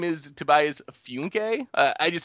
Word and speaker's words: Funke: So it was driving me crazy that it wasn't Funke there.